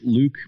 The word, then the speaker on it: Louque: You can find me on Twitter at Jake Luke. My last name is spelled L-O-U-Q-U-E for some reason.